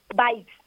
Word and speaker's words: Vibes: Local Vives, Vives, ¿no? – Vibes, Vibes. – Ah, Vibes. – Local Vibes.